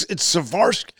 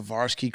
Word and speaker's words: Swarovski: It's Swarovski. So am I pronouncing that correctly? – Yep. – Swarovski. – Mm-hmm. – Oh, my mother loves a Swarovski crystal.